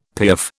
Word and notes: piaffe: British English

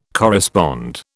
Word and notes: correspond: British English

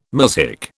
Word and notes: muzhik: British English

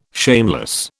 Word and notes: shameless: British English

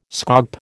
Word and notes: scaup: American English

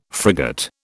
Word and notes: frigate: British English

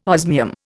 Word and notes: osmium: Indian

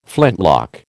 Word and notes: flintlock: American English

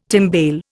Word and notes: timbale: Indian

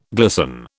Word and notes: glisten: British English

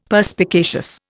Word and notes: Perspicacious: Indian

